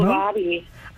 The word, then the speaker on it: kohlrabi: I want to talk quickly, and you may have to correct me on my pronunciation. Kohlrabi, that's a vegetable. – Kohlrabi. – There we go. – Yeah, kohlrabi. – Kohlrabi, got it.